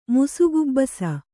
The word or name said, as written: ಮುಸುಗುಬ್ಬಸ